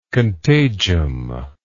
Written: contagium

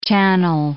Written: channel

